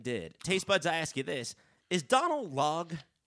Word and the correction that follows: Donal Logue: am I saying that right? – I always pronounce it as Donal Logue.